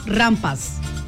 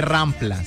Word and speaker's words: rampa: No, que no, no, que no se dice rampla, se dice rampa.